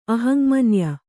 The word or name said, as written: ಅಹಂಮನ್ಯ